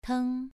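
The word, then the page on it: teng: tone 2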